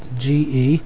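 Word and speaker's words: ge (γῆ): It's spelled G-E. It's pronounced ge.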